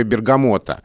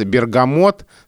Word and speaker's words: Бергамо: У нас на грамоте был вопрос, бергамот, связан ли он с названием итальянского города, которое, как мы знаем, звучит по-разному, Бергамо или Бергама. Бергамо... – Но никак не Бергамо. Да, Бергамо ближе к итальянскому произношению, а Бергамо вошло уже в русскую культуру с таким ударением, Труфальдина из Бергама, и мы, конечно, это помним, и нам сложно говорить Бергамо.